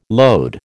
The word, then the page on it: load: American English